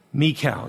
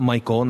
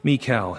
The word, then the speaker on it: Michal: Third deliverance, verses 11 to 17, by the hand of his wife, Michal. You'll hear people say Michal. Jars my ears because it's not Michael, not pronounced Michael in the Hebrew,